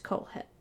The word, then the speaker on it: Kohlhepp: And I know that's how you pronounce it because I watched like 5 documentaries. And unless every single one of those detectives pronounced it wrong, it's Kohlhepp.